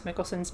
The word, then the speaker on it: Mikkelsen: And then Mikkelsen is right, like Mikkel rhymes with nickel.